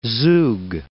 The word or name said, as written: Zoug